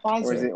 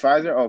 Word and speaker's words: Pfizer: No, Pfizer. – Or is it Peace? It's Pfizer? Okay, so the P is silent.